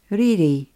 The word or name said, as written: riri